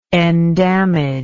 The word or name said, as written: endamagement